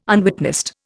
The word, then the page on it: unwitnessed: Indian